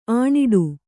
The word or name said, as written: ಅನಾಡಿ